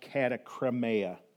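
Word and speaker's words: katakrima: And God is so good that that word, first of all, to really drive this home, is that word condemned in the Greek is pronounced katakremeia, and you guys can laugh at me.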